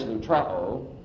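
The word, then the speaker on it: lutrao (λυτρόω): One is lutrao, that's how that's pronounced, to release on receipt of a ransom.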